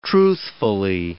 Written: truthfully

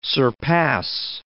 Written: surpass

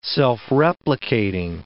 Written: self-replicating

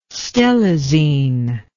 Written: stelazine

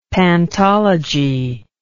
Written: pantology

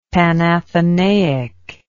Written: Panathenaic

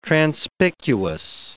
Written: transpicuous

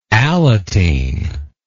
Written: Alateen

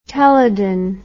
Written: Telidon

